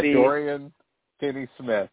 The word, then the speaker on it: Dorian Finney-Smith: Dorian Finney-Smith.